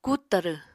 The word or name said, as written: kut tarw